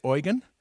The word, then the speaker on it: Eugen: Now, does that look like you would say Eugen? I've learned that that's how his name is pronounced. It looks to me like Eugene, but he's the field leader there.